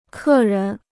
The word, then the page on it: 客人: kè rén